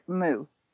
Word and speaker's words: Mu: And we pronounce it Mu, like Mu the cow.